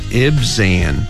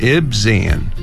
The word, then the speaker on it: Ibzan: Ibzan. IB이기 때문에 Ib. Ib. 그리고 Zan. Ibzan.